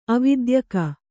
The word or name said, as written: ಅವಿದ್ಯಕ